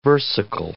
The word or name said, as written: versicle